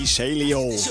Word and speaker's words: Easilio: Isilio. Se diría así como en estadounidense, en yankee, en anglosajón. Si lo buscas escrito, se escribe Easilio.